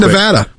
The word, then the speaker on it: Nevada: All right, Rick in Nevada. – Nevada, you said it right. – I always say it right.